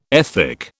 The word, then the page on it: ethic: British English